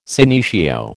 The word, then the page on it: senecio: American English